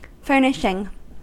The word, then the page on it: furnishing: American English